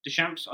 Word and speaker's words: Deschamps: Bringing Mbappe Deschamps. – On. – Deschamps? I don't know how to say his name. – Yeah, it is.